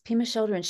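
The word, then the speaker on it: Pema Chödrön: but did you say her name is Pema Chodron? – Yes, Pema Chodron.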